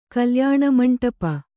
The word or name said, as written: ಕಲ್ಯಾಣಮಂಟಪ